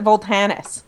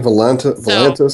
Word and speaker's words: Volantis: How do you pronounce it? – I always pronounce it Voltanis. – Okay. – Well, it's definitely Volantis.